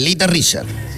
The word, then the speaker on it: Little Richard: Bueno, y otro es el Little Richard.